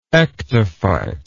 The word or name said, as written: ectophyte